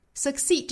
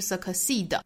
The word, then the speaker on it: succeed: Succeed 这个词大家注意中间的发音非常轻弱，后面不要拖音，不是 succeed, 而是 succeed, succeed, succeed.